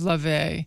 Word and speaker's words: Laufey: It's spelled L-A-U-F-E-Y, but I know from reading it that it's Lave.